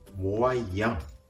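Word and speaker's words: moyen: 発音のポイント、これは語尾のモアイアンの微母音、アンです。ボインはカタカナ発音、モアイヤンと発音しながら同時に、ヤンをいうときに鼻から息を抜かすように、モアイヤン、モアイヤンと発音するところがポイントです。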